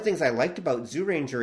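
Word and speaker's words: Zyuranger: It can be, I should point out, it can be pronounced Jew Ranger, or it should technically be Zoo Ranger. Like, there's a weird sound there that we're kind of skipping around.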